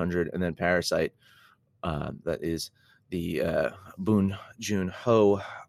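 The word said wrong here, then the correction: Bong Joon-ho: uh, Bong Jun Ho.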